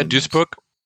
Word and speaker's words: Duisburg: You did, yeah, Duisburg.